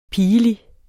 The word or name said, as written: pigelig